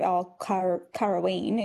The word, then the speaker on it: Al-Qarawiyyin: It's called the University of Al-Qarawiyyin. It's a very difficult word to say if you don't know. – And it's that emphasis on the Y.